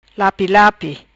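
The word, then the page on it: Lapi Lapi: patua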